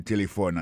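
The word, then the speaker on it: telephone: Nous avons remarqué que l'accent tonique l'a tombé sous premier syllabe là. Si nous besoin de dire bien, c'est téléphone. Alors, vous prononcez ou appuyez plus sous premier syllabe là, qui c'est T.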